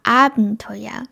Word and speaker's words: Abenteuer: 2 番は Abenteuer。これは一番初めにアクセントがあるんですけど、 Abitur。これは Tour にアクセントがあるんですね。 Abenteuer。